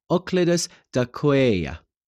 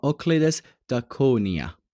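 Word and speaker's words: Euclides da Cunha: اوکلیدس داکویا یا اوکلیدس داکونیا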